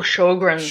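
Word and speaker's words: Sjögren's: Surgeons, how do you say that? It's S-O-G. Why would you put a J after an O? – Oh, Sjogren's. – Sjogren's. Okay.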